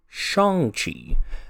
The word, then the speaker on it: Shang-Chi: That's kind of his post that he posted during Asian Heritage Month, how it's actually supposed to be pronounced. Um, he also pronounced how the name is supposed to be said, which he says Shang-Chi.